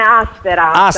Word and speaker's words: Asteras: Associazione Asteras. Accento sulla prima A.